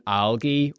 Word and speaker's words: algae: If you are vegan, algae, or do you guys say algae? – Algae, yeah. We have a soft G on it. Algae.